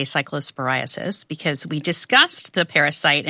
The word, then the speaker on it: cyclosporiasis: If you are worried, there is a CDC cyclosporiasis. I didn't pronounce that right. – Outbreak cyclosporiasis. Pay. Yes.